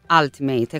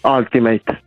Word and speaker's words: Ultimate: Scritto Ultimate, lo pronunciamo Ultimate, è corretto? – Ultimate.